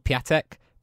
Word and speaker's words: Piątek: It's the guy that you think is called Piątek, but his name is actually pronounced Piątek.